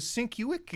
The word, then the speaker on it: Sienkiewicz: That's pronounced Sienkiewicz? – Yeah. – Sienkiewicz? – I thought it was Sienkiewicz. Is it? – That's exactly what it is.